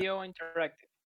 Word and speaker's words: IO Interactive: IO Interactive.